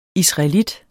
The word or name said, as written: israelit